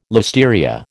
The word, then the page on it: listeria: American English